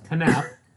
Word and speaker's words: Knapp: Moving on to our to our oral report for the evening, which is State of Decay by James, I'm going to say Knapp, unless you correct me. Yeah. I assume the K is silent. – No, no, it's Knapp.